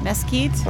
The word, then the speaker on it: Meeskite: But we're starting off the show with Johnny Mesquite. Is that how you pronounce that? – Mesquite.